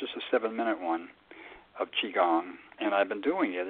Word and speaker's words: Qigong: And what you were talking about, my pronunciation is not going to be right. Qigong, is that right? – Oh, Qigong, yeah.